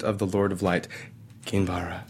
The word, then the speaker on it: Kinvara: Is that how we say her name? Kinvara? – Yes, if you want to know the full name.